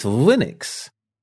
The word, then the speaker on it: Linux: But it's Linux.